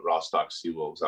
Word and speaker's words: Rostock Seawolves: No, yeah, that's how it's pronounced. I struggled that first when I first got here pronouncing that too. So yeah, it's the Rostock Seawolves out in Germany.